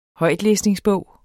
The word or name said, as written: højtlæsningsbog